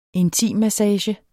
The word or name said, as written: intimmassage